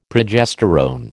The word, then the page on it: progesterone: American English